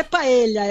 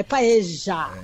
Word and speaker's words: paella: Tem, é, não é paella, é paeja.